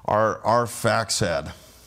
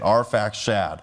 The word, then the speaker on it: Arphaxad: And that's actually spelled two different ways. One's got an X and the other one's an SH, but it's Arphaxad. I've kind of butchered it a couple times, but that's how we're going to say it. Arphaxad.